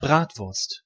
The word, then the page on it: Bratwurst: [ˈbraːtvʊrst]